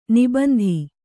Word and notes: ನಿಬಂಧಿ: nibandhi

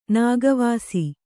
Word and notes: ನಾಗವಾಸಿ: nāga vāsi

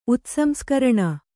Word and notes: ಉತ್ಸಂಸ್ಕರಣ: utsamskaraṇa

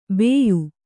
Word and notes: ಬೇಯು: bēyu

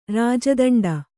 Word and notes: ರಾಜದಂಡ: rāja daṇḍa

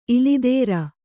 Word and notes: ಇಲಿದೇರ: ilidēa

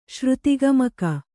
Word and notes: ಶ್ರುತಿಗಮಕ: Kannada

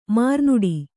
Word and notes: ಮರುನುಡಿ: kannada

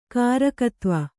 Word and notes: ಕಾರಕತ್ವ: kannada

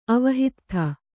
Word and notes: ಅವಹಿತ್ಥ: avahittha; kannada

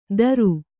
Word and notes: ದರು: Kannada

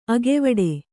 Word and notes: ಅಗವಡು: kannada

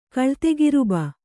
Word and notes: ಕಳ್ತೆಗಿರುಬ: kaḷtegiruba

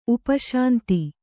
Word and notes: ಉಪಶಾಂತಿ: upa śanti; kannada

